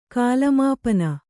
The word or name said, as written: ಕಾಲಮಾಪನ